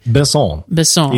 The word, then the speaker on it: Besson: The director is Luc Besson. I don't know if I have said that correctly. – Besson. He's French.